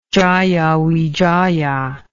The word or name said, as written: jayawijaya